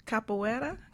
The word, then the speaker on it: capoeira: Has capoeira, mm-hmm. am I saying it right? – Got it.